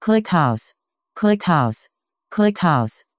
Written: clickhouse

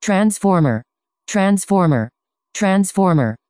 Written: transformer

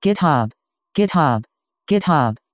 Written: github

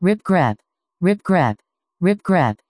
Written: ripgrep

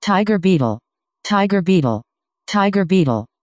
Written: TigerBeetle

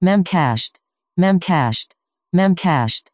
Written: memcached

